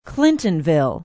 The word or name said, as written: clintonville